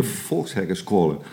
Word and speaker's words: Volkshogeschool: En we zitten hier op de Volkshogeschool. Eh, zeg ik het goed? Ja, de eh, Volkshackerschool. – De Volkshackerschool. Ja.